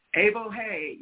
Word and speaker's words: Evohe: It's spelled I-O-E-O, which is an exclamation of joy. Evohe, E-V-O-H-E. Yo, Evohe.